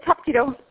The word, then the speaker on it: Hapkido: Am I saying that correctly? Yes, it's Hapkido.